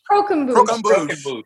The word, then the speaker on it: croquembouche: How do you say it, Siri? – Croquembouche. – Yes.